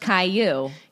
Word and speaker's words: Caillou: Like, is it Caillou? – I think it's Caillou. – Caillou. – Yeah. So yeah, that's my. – Oh, it is. It's pronounced Caillou.